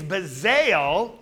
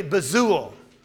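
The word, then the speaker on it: Bezalel: In your Bible, it's B-E-Z-A-L-E-L. And it's Bezael in English, but the Hebrew pronunciation, I understand, is more correctly Bezuel.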